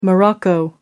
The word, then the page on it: Morocco: American English